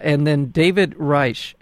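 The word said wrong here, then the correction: Rasche: Am I saying that right? – David Rache, who does this brilliant.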